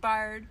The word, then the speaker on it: Bard: How do you pronounce it? – Bard. – Bard, okay.